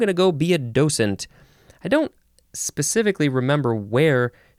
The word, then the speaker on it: docent: And I guess you can also emphasize the second syllable, and it's docent. And there is a little bit of a T sound. It's like there's a T in parentheses in the pronunciation guide. Docent. D-O-C-E-N-T.